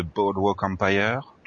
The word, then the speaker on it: Boardwalk Empire: Dimanche 22, 20h10, les deux premiers épisodes de Boardwalk Empire, toujours sur RTBF2, ou par deux.